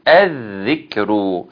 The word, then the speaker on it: الذكر: وقالوا يا أيها ثم يا أيها الذي نزل عليه الذكر، عليه الذكر اللام هنا شمسية حكمها الإضغام لا تنطق فنقول الذكر إنك لمجنون.